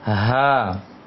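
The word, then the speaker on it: Ha (ه): Kalau yang ini, yang terakhir ini, ha tenggorokan bawah. Ha, mulai di sana, mulai di tenggorokan bawah, ditiupkan keluar anginnya. Ha.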